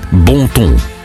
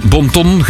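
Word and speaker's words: bon ton: Bonton. – Ah, het is niet een ponton. – Nee, bonton geschreven, maar je moet het bonton uitspreken.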